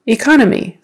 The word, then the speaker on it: economy: Economy, economy, second syllable stress.